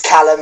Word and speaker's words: Calum: It's Calum.